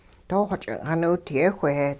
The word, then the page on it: doo xo-ch'ing' xiniwidiyehwh-heh: hupa